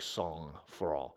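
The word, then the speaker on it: sang-froid: And so Sang-Froh leadership, Sang-Froh, I think is actually, if you look at my pronunciation guide, it's like Sang-Froh, is to rest in God's ability to do it.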